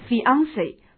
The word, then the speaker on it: fiancé: 那么未婚妻是 fiancé Fiancé 这是法语词，注意发音比较特殊。